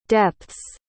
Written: depths

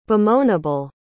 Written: bemoanable

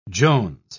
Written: jones

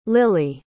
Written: lilly

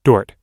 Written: dort

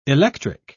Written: electric

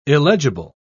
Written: illegible

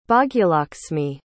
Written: Bhagyalaksmi

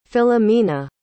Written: Philomena

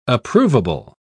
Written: approvable

